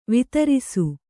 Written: ವಿದಾರಿಸು

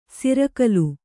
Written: ಸೂರುಗಲ್ಲು

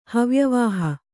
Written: ಹವ್ಯವಾಹ

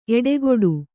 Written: ಎಡಗಡೆ

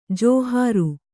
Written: ಜಾಹಿರು

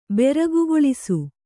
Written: ಬೆರಗುಗೊಳಿಸು

